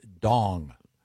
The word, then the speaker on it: Duong: Last name is H-U-N-G Hung. His first name is Dong. – Yeah. – It's, okay. – Oh. – It's D-U-O-N-G. I would say. – The U is silent.